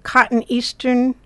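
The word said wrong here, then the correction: cotoneaster: I think that's cotone aster. – Well, pronunciation isn't one of my fortes, and you are correct.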